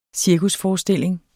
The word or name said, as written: cirkusforestilling